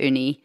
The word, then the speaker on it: Uuni: Basically, there was a few good reasons, but one of the key things is that U-U-N-I, we knew to pronounce it Uni, but not everyone did.